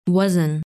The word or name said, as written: Wazn